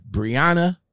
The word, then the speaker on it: Breonna: Say Breonna Taylor. Say, is it Breonna? Yeah, Breonna Taylor.